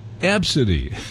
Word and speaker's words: Abcde: The name A, B, C, D, E, pronounced Absidy. Absidy for a name for a child.